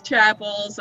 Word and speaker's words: Chappells: It's Chappells, and yeah, we get that a lot. We've answered to Campbell's before, so it's, you know, we get quite a bit.